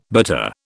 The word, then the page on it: bitter: British English